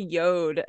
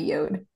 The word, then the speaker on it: Yod: It's spelled Y-O-D. I've always heard it called Yod, but logically it would make sense to be Yod, and it would only be Yod if there was an E at the end, but that's what I've always heard it called.